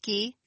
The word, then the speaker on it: ghee: Is it pronounced ghee? – Yeah, ghee. Clarified butter. You got it.